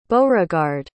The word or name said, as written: beauregard